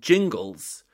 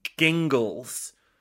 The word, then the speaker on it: Jingles: The first syllable in Jingles begins with a soft G, Jingles. So a hard G would be Gingles. As in, so he says, as in a shot of the juniper-infused alcoholic spirit, often paired with tonic and oversized bulbous glasses. That's gin. Therefore, the name is Jingles. Jingles.